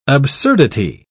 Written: absurdity

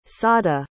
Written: sada

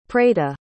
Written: preita

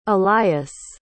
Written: elias

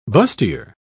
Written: bustier

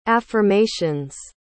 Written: affirmations

